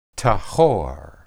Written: tahor

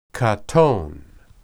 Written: qaton

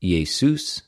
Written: Ἰησους

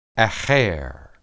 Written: aher

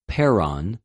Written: πέραν

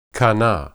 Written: qanah

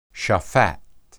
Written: shaphat

